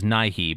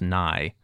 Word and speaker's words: Nighy: And I always thought it was Nighy, but you have said, and I think you're right, that it is just pronounced Nye.